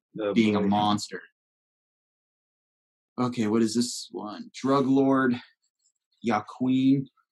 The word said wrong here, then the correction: Joaquin: That was a shockingly American pronunciation. – Yeah, I went as sharp as I could. – El Chapo. – Joaquin.